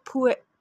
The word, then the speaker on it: pu'er: My personal favorite for now is something called pu'er. We call it pu'er, but I think in some parts of the world you call it pu'er. Well, it's spelled P-U-E-R-H, I think, pu'er.